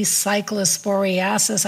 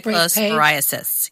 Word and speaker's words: cyclosporiasis: If you are worried, there is a CDC cyclosporiasis. I didn't pronounce that right. – Outbreak cyclosporiasis. Pay. Yes.